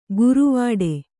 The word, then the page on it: ಗುರುವಾಡೆ: guru vāḍe